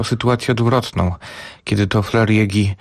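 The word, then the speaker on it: Fleur Jaeggy: To pisarka, której też nazwiska długo nie wiedziałem, jak wymawiać, ale wszystko wskazuje na to, że skoro urodziła się w Zurychu, to musimy z niemiecka, więc Flair Jägi.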